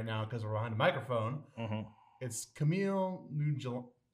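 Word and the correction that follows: Kumail Nanjiani: How do you say his Kamal Nanjiani.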